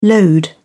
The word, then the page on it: lode: British English